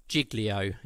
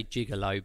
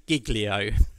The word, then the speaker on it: Giglio: There's an American pastor, Louis Giglio, is how I think you pronounce it. I always want to say gigolo, but that's something very different. Louis Giglio.